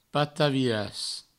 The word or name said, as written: pataviras